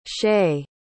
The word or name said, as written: chay